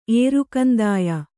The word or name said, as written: ಏರುಕಂದಾಯ